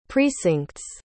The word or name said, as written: precinct's